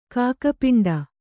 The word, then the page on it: ಕಾಗೆಪಿಂಡ: kāge piṇḍa; Kannada